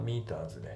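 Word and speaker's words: meters: これは先ほどのミーター、ミーターズでその引っ掛けの問題ですね。ちなみに今回のミーターは正しくというかしっかりね発音されてます。ミーターズ、まあ T のところが D でちょっと濁るような感じなんだけど、ミーターって感じで発音されてますね。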